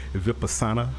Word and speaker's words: Vipassana: I've been reading about you, and you studied and help me if I'm not pronouncing this correctly, but Vipassana.